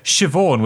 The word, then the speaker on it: Siobhan: Yeah, Siobhan with a silent B.